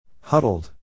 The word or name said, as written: huddled